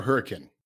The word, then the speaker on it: Hurricane: Is it hurricane or hurricane? – Hurricane. – Hurricane. Damn, that yeah. was hurricane. Like you know. Correct pronunciation is hurricane.